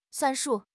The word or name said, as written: 算数